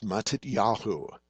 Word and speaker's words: Matityahu: In Hebrew, it's pronounced Matityahu. Matityahu.